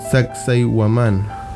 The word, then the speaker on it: Sacsayhuamán: No sé cómo pronunciar esto. – Sacsayhuamán. ¿Viste? Lo pronuncié bien.